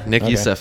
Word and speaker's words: Nick Youssef: Yeah, Nick Youssef, that's correct.